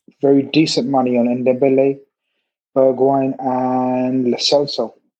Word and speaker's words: Bergwijn: You know, they signed the guy, is it Bergwijn? Is that how you pronounce it? – Bergwijn, yeah.